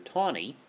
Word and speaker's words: Taney: Chief Justice Roger Taney, spelled T-A-N-E-Y, but it was an old Maryland family, and that's how they pronounced the spelling of T-A-N-E-Y.